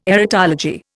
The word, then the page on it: erotology: Indian